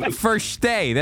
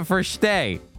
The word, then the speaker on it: verstehe: Verstehe. That's how you say it. Verstehe.